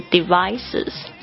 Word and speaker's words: devices: 就是 devices, devices, D-E-V-I-C-E-S. 就注意一下这个 V 的发音，我有听过太多的小伙伴不知道为什么会发成 V 的声音，不是 devices，是 devices，你的这个前排的牙齿应该是轻轻的碰触到这个你的下嘴唇， devices。